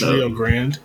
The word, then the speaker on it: Rio Grande: Like, well, I thought it was Rio Grande University. – It's Rio Grande.